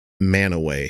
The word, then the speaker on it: Mantua: It's spelled Mantua, you know, like the town in what is it? Oh, it's Verona that's in Romeo and Juliet. But anyway, Shakespeare has Mantua a lot of the time in some of his stories. And they pronounce it Manaway.